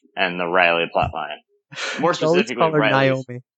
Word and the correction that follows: Nomi: You like adding syllables tonight. – I know. What's her name? – Nomi. – So she's like a gnome.